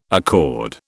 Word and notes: accord: British English

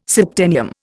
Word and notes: septennium: Indian

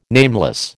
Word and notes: nameless: American English

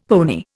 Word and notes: pony: Indian

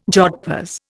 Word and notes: jodhpurs: Indian

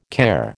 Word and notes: care: American English

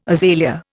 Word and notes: azalea: Indian

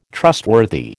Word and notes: trustworthy: American English

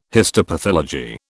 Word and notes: histopathology: British English

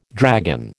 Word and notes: dragon: American English

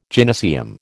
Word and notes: gynaeceum: American English

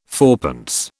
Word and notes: fourpence: British English

